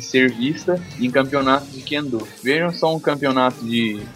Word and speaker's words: Kendo: Aqui no caso tem, aqui no caso é Kendo a pronúncia.